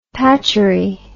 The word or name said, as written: patchery